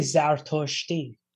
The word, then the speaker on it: Zartoshti: How do you say that in Farsi? – Oh yeah. Yeah, you say Zartoshti. Zartosht.